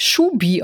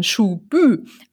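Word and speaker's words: Schuby: Der Ort wird gar nicht Schubi ausgesprochen, sondern Schubü, also hinten mit Hü.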